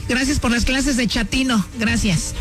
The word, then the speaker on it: chatino: Chapino. – ¿Chapino? – No, chatino. – Es como chato, ¿verdad? Chatino. – Sí, la primera como chato, chatino.